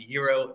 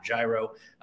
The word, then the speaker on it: gyro: And yes, it is pronounced gyro, not gyro. That's how I grew up pronouncing it in Detroit, so we'll go with how it's supposed to be done.